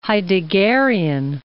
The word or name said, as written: Heideggerian